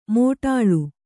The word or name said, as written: ಮೋಟಾಳು